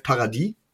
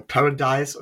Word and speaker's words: Paradis: Meiner Meinung nach aber einen relativ, ja, lange Zeit einer der besten mit auf dem Markt war wir reden hier von Matt Paradis oder Paradise oder, naja, Paradise wird es ja nicht ausgesprochen, ist ja ohne E am Ende.